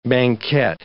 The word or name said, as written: banquette